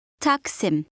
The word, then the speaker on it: Taksim: Taksim. Sim. Taksim. Taksim. – Now try to say Taksim Square.